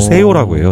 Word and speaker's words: sello: 세요라고 해요. 세요. – 네네. – 그러니까 스펠링으로는 S-E-L-L-O.